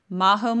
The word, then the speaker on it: Majo: So I'm available on Instagram at Majo Molfino. Majo spelled with a J, so that's M-A-J-O.